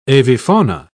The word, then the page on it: avifauna: British English